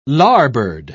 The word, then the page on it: larboard: British English